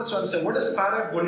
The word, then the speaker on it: parabole: Parable, the original Greek word, it's pronounced as parabole, okay?